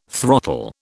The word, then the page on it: throttle: British English